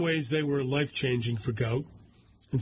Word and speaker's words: Gout: In 2005, Bona, B-O-N-A, and Monica Gout, pronounced Gout. Gout, spelled G-O-U-T, is pronounced G-W-O-T, Gout, sought to leave war-ravaged South Sudan.